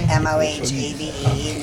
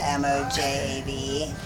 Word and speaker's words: Mojave: Mohabe, not the Mojave. – M-O-J. – And M-O-J.